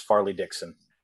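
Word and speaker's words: Fairleigh Dickinson: And I, at no point is it ever like, oh, let me sit down and watch, you know, Lipscomb versus Farley Dickson.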